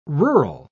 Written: rural

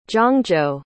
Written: zhangzhou

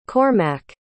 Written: Cormac

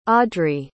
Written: audri

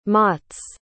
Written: motts

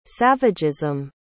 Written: savagism

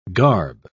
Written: garb

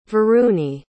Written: varuni

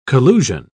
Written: collusion

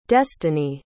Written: destany

